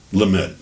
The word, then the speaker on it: Lamed: Then we have the Lamed. Did I say that right? – Lamed. That's how I say it.